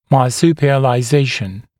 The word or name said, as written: marsupialization